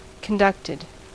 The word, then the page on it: conducted: American English